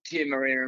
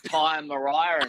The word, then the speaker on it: Tia Maria: Oh, and then I said, and I said, is your favourite drink still uh, Tia Maria, and milk? but I pronounced it Tyre Mariah and milk.